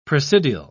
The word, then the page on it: presidial: British English